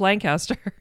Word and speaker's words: Lancaster: And I ended up majoring in meteorology at Millersville University in Lancaster, Pennsylvania. I put emphasis on Lancaster because I'm always made fun of for pronouncing it incorrectly, apparently, the way I've pronounced it all my life, Lancaster.